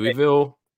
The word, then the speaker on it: Louisville: Especially when it's your name's Louisville, and you pronounce it right. Louisville. Fucking pretentious pricks.